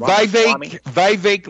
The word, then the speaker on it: Vivek: Vivek like cake.